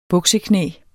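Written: bukseknæ

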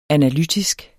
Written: analytisk

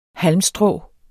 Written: halmstrå

